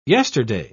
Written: yesterday

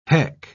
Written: heck

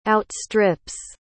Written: outstrips

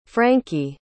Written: franki

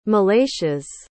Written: malachias

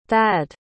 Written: thad

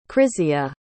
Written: krizia

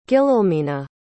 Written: guillelmina

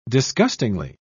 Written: disgustingly

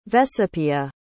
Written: vecepia

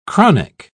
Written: chronic

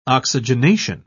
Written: oxygenation

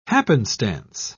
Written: happenstance

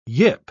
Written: yip

